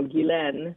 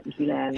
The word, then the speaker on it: Ghislaine: Oh, well, this is huge because, you know, Ghislaine, that's how you say her name, Scott. Ghislaine.